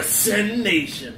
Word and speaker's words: SYN Nation: I've always found it funny that it's SYN Nation, kind of pronounced like Sen, and /TNS, TNS, aka Tits and Ass. – Fuck it. – Go to Sen Nation for Tits and Ass.